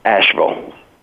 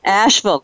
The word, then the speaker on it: Asheville: Asheville. – Asheville, got it.